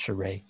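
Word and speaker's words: Charest: It's spelled Charis, but I think it's Charé.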